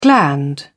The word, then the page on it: gland: British English